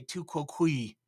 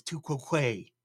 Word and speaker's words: tu quoque: I looked it up on how to pronounce it. You can also say tu quoque, but the more correct Latin is tu quoque.